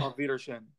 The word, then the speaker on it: Auf Wiedersehen: Auf Wiedersehen.